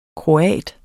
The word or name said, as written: kroat